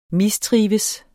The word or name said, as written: mistrives